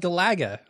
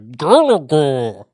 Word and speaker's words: Galaga: It should be in bad 80s voiceover, like Galaga. – Galaga. How do you pronounce it? – Galaga. – And I said Galaga? – Yeah. You put the emphasis on the wrong syllable.